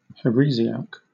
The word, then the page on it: heresiarch: British English